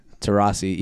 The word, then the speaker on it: Taurasi: The Goon Squad villains will include Damian Lillard, Klay Thompson, Anthony Davis, Diana Taurasi, and I'm not even going to try Taurasi. What'd I say? – Taurasi. – Taurasi, yeah.